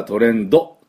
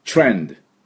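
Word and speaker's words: trend: で、 D はトレンドっていうふうに言っちゃいけない。ドをつけてはいけないから、トレンド、トレンドというふうに発音します。ね、チュッチュッと最後、ドゥッて止まるっていうのがポイントです。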